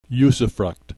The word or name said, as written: usufruct